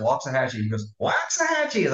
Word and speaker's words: Waxahachie: You're in Waxahachie. Did I say that right? Waxahachie. – Waxahachie.